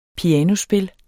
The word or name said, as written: pianospil